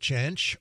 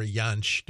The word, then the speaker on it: Jansch: And then finally, we heard Bert Jansch, or Jansch, depending. He pronounces it, the J, as a J, but it's from German, so it also could be the Y.